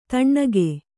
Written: ಥಣ್ಣಗೆ

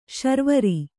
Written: ಶಾರ್ವರಿ

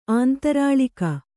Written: ಅಂತರ್ಲಾಗ

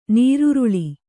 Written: ನೀರುರುಳಿ

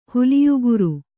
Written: ಹುಲಿಯುಗುರ್

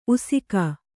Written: ಉಸಿಕ

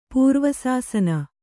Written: ಪೂರ್ವಸಾಸನ

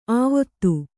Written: ಆವೊತ್ತು

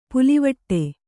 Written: ಪುಲ್ಲಿವೆಟು

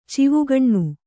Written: ಚಿವುಗಣ್ಣು

